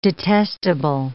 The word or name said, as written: detestable